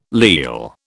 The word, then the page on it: leal: British English